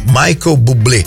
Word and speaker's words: Michael Bublé: Michael Bublé.